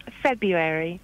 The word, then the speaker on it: February: Could you please say February properly? – Could I say you... – what? Sorry? – February. – I am saying it properly, Kerry. You're missing out one of the key ingredient letters.